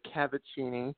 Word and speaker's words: Cavacini: Crystal, you got the name right. It's Cavacini. I did my best to spell it out for you phonetically, because people say Cavacini. I've had people say Kavinsky and make me Polish, so that, you know, people butcher last names constantly.